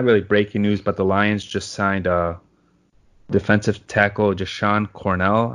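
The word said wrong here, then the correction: Jashon Cornell: I think it's Jason Cornell. What? – Jason. Okay, my bad.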